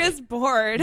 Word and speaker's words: smorgasbord: You said it like it rhymes with lordis. The smorgasbord. – It's my Valley Girl accent from Ukraine. I can't help it. – Say it one more time. – Smorgasbord. – There you go.